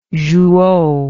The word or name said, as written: jouhaux